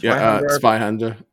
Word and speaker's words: Zweihänder: The Zweihänder. Is that how you pronounce it? – Zweihänder, which is a two-hander in German.